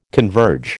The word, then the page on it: converge: American English